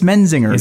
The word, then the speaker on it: Menzingers: I just missed Menzingers. You know, they do sound familiar. – Right. I always said Menzingers. And I was like, nope, it's Menzingers.